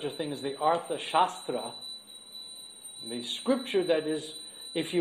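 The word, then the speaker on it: Shastra (शस्त्र): Shastra means scripture, wisdom text, with a long A, Shastra. Shastra, with a short A, means weapons.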